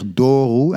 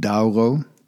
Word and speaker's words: Douro: Het valt je waarschijnlijk wel op, ik zeg Doru en niet Douro. In het Portugees wordt de au als een o uitgesproken en de o vaak als een oe, Douro dus.